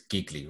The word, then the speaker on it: Gigli: But yeah, we chose Gigli, spelled like really, but with a G. – But for everyone in the world would know this film as Gigli, right? – Gigli, yeah.